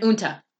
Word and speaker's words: Uinta: Uinta Basin? Because I can't read today. – Uinta. Yeah, Uinta okay.